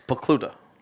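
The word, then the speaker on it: Pokluda: JP Polduck or whatever you said over there, mispronouncing the gentleman's name. – It's Pakluda.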